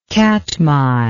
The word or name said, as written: katmai